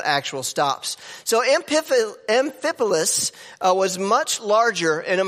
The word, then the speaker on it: Amphipolis: Now, when they had passed through Amphipolis, I did say that right, and Apollonia.